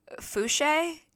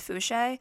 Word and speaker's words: Fouché: It's, I think I'm saying her name right, Fouché. It's like Touche, but it's Fouché.